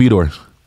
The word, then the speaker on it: Vitor: Remember, because I was pronouncing his name wrong. – Oh, yeah. Okay. – Yep, I picked Vitor. – Oh, I was calling him Victor.